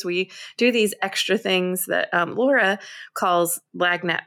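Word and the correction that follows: lagniappe: I'm going to so pronounce this wrong. You're from Louisiana. – Lanyap.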